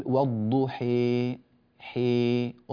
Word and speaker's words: والضحى: فنقول كيف ننطقها؟ نقول والضحي حي، انظروا إلى صوت الألف الضحى، ولو قلبناها ياء في غير القرآن لنقول الضحي، لكن في الإمالة الكبرى حي، هكذا هكذا صوتها.